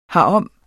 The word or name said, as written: herom